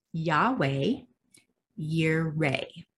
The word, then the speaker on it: Yahweh Yireh: Now, a lot of us know this name as Jehovah Jireh, but in the Hebrew, it's actually Yahweh Yireh, I believe is how you say it. Yahweh Yireh, okay?